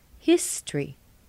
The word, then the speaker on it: history: History. What do you hear? Or rather, what don't you hear? That O, there's no O sound. It's not history. It's history.